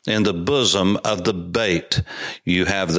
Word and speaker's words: Beit: The modern Israeli pronunciation is Beit, B a I T, for instance, would be the way that you would say it and enunciate it in English.